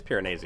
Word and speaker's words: Piranesi: I think it's Piranesi. – Piranesi. – Oh yeah. That's how it's Italian.